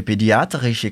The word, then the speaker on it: pediatrische: A secretária fica surpresa e diz: Also, aber Sie rufen eine pediatrische Klinik an.